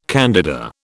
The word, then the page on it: candida: British English